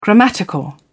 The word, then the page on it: grammatical: British English